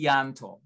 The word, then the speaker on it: Tianto: My name is pronounced Yudha T. Tianto. So the okay. D is hard and the T is hard.